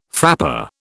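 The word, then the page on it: frappé: British English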